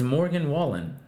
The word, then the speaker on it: Morgan Wallen: How do you say it? – It's Morgan Wallen.